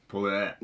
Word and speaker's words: Poirot: Eh, men han har jo da fått for seg at han syns det virker gøy å spille Hercule Perot. – Perot. Mm. – Poirot. – Mm. Er det ikke det er det? så, så Poirot, som vi sier.